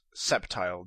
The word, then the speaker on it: Sceptile: But first of all, Sceptile is not Skeptile, it's Sceptile. The C is silent.